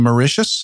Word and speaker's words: Mauritius: If you're listening from Mauritius, did I say that right? – Yes, you sure did.